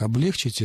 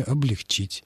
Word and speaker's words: облегчить: Облегчить — это очень распространенная ошибка. Правильное ударение — облегчить.